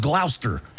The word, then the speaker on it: Gloucester: But then you go, it's pronounced Worcester. – Because Gloucester looks like that, but it's pronounced Gloucester.